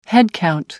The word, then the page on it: headcount: American English